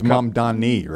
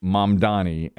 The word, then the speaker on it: Mamdani: It's Mamdani, right? – That's correct. It is Mamdani. And that is one of the most mispronounced words of the year. There's a few more here, too. Mm-hmm. These are not having anything to do with Google. This was announced. That was number one. Zoran Mamdani is his name, and that's how you pronounce it.